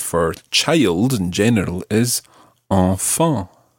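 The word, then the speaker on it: enfants: And the plural of this, the word for children, it sounds exactly the same, although it's got a silent S on the end. So you would say enfant.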